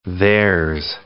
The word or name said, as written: there's